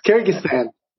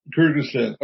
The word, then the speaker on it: Kyrgyzstan: Could you pronounce it for me? – Yeah, it's Kyrgyzstan. – Kyrgyzstan. Okay. I didn't want to make a mistake and embarrass myself.